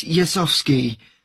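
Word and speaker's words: Jezowski: It's Yasovsky. I'll spell that for you. It's J-E-Z-O-W-S-K-I.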